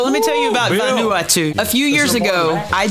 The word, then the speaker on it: Vanuatu: Vanuatu. – Vanuatu.